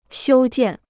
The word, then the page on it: 修建: xiū jiàn